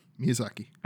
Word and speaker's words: Miyazaki: I see what you're saying now, because Dark Souls is one of those games where, let's be honest, Miyazaki, is that how you pronounce it? – Yeah, Miyazaki.